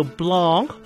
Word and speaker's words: Blanc: 个踢过曼联嘅后卫叫 Blanc，最近读音系单字一个邦或者布朗，但香港人用英文读亦做白兰斯。